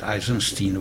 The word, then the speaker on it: Eisenstein: Tehát megnéztem egyrészt a tehát megnéztem, sokat írsz Bernice, hogy ejtik angolosan a nevét, Eisenstein vagy? – Igen, úgy.